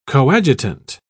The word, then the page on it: coadjutant: British English